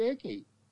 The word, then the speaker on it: Icke: David Icke can be Icke, and he can be Icky.